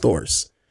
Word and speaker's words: Thors: And his name is Thors. Almost like Thor, but it's pronounced Thors.